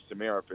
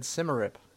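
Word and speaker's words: Symarip: Because growing up in England, we always said Simmerip, which is you're 100% correct. Simmerip, Samarup.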